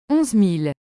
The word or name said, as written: 11000